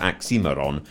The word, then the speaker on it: Oxymiron: His stage name is Oxymiron, and with a Moscow pronunciation, Oxymiron.